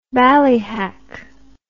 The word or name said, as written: ballyhack